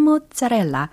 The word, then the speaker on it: mozzarella: Uh, my father, he, you know, he believes that pizza should be round with sauce and mozzarella. – 아, 우리 아빠는 uh, you know 너도 알잖아. He believes that pizza should be round with sauce and mozzarella.